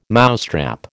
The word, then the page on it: mousetrap: American English